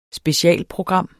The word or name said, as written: specialprogram